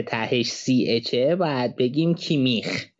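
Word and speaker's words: Kimmich: شما آلمانی بلد نیستی سال نظر نکن اولا که یه مصاحبه داره کیمیش چون که اسم کوچیکش یوسوا تلفظش توی آلمانی هم عجیب غریبه یعنی اسمی که شنیده نشده ازش پرسیدن اسمش تلفظ کنه اون مصاحبه موجوده تلفظ اسمش رو از زبون خودش میتونی بشنوی یوسوا کیمیش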